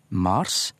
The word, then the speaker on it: Mars: Og dette er da også forklaringa på at det er god tradisjon i norsk for å uttale gudenavnet med lang A, Mars, men eh, månadsnavnet, som jo faktisk har egentlig et litt annet bokstav opphav i latin. Det blir uttalt med, med kortvokal, mars, og på dansk altså skrevet med ts.